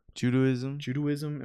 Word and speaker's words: Judaism: Is it Judaism? Is that the right word for them? – Judaism. – Am I saying it right? I was about to say Judaism. I couldn't say it right.